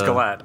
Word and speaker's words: Gillett: Is it a hard G? – Or I think it is Gillette. I think it's Gillette, yeah. – It's not like the Razor, which is a soft G for those out there.